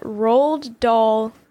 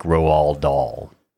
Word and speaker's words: Roald Dahl: In the last year or two, I've heard it said that apparently his first name is supposed to like rhyme with his last name, so I think it's supposed to be like Roald Dahl.